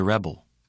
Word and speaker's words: rebel: In the following words, the nouns are stressed on the first syllable. The verbs are stressed on the second syllable. Repeat these words. – The present. To present. The rebel.